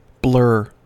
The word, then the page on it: blur: American English